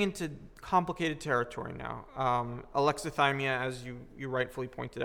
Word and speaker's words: alexithymia: alexithymia. I think I'm pronouncing that right. – Yeah, that's right.